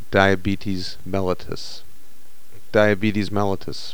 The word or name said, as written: diabetes mellitus